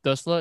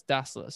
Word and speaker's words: Dassler: So to my thinking is it's not Dussler, it's Dassler.